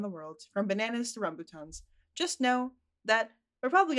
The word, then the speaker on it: rambutan: from bananas to rambutans. I didn't say that correctly. Whatever. – No, rambutan is how you pronounce it.